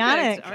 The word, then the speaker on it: Pionek: Pionek? – Yes, okay. Correct. – Perfect. – Pionek.